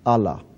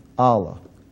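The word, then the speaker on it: Allah (אלה): It's spelled Aleph Lamed He, curse. It's pronounced Allah. Now, the word or the name of the God of Islam would be spelled Aleph Lamed Lamed He, but it's pronounced exactly the same way.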